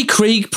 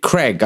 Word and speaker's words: Krieg: Yeah, but they can't say Krieg properly. Like, they can't say Krieg. Like, I'm sorry. And it, like, Americans and fucking Canadians, they butcher the word Krieg. They fucking pronounce it Craig.